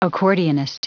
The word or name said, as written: accordionist